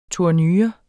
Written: tournure

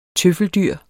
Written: tøffeldyr

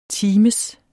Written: times

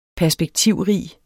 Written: perspektivrig

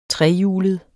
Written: trehjulet

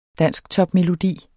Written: dansktopmelodi

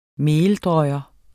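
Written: MELDRØJE

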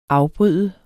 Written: afbryder

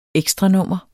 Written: ekstranummer

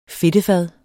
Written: fedtefad

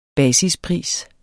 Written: basispris

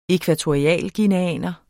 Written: ækvatorialguineaner

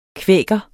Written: kvæker

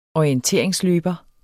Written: orienteringsløber

